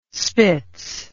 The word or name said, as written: spitz